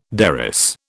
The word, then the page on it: derris: British English